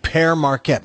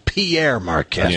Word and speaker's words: Pere Marquette: Thank you for saying it right. Pere Marquette. Pere Everybody Marquette. Says Pierre Marquette.